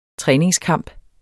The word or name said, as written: træningskamp